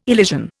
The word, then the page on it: elision: Indian